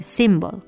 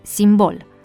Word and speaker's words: simbol: În cazul cuvântului simbol, accentuarea pe prima silabă întâlnită frecvent poate fi explicată și prin influența limbii engleze, symbol. Totuși, corect este simbol.